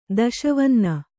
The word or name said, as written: ದಶವನ್ನ